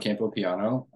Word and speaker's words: Campopiano: So my name is John Campopiano.